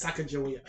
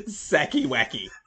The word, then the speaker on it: Sacagawea: So how does Regis, God rest his soul, pronounce Sacagawea? – So he's given all the answers and he gets to this one and he goes, Sacky Wacky.